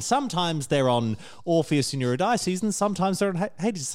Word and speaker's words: Eurydice: Also, it's pronounced Eurydice.